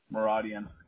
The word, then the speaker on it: Muradyan: Muradian. – Oh, how do you spell that one? – M-U-R-A-D-Y-A-N. – Got it. So M-U-R-A-D-Y-A-N.